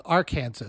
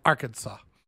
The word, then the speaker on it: Arkansas: I live in Kansas, but down below it's not called Arkansas. It's called Arkansas.